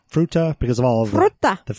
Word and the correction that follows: Fruita: You're adding extra vowels. Fruita. – Fruita.